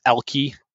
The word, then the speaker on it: Elki: Um, it's pronounced Elki, E L K I yes like, underscore yes.